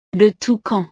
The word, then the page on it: le toucan: frances